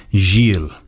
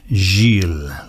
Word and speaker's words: Gil: Gil. Soft Gil, like that, yes.